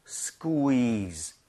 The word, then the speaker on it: squeeze: 스퀴가 아니라 쿠이로 다 나눠서 하셔야 돼요. 스퀴즈 아니라 squeeze.